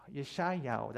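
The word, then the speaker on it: Yeshayahu (ישעיהו): And in the Hebrew language, it's Yeshayahu, that's how you say it.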